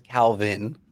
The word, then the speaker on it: Calvin: Right, Like Calvin Klein, Calvin Hobbes and um, to say my name with with a Mexican accent, right, would be Calvin, right? Okay. Um, and so that kind of sounds like cow beans.